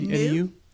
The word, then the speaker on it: NEU: New? – We called it NEU. Yeah, we called it NEU, new for neuroblastoma.